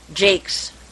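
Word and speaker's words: Jacques: Just a quick note that the next meeting is on March 20th, and we're doing Redwall by Brian Jakes, as they pronounce it over there across the pond. It's J-A-C-Q-U-E-S for anyone looking.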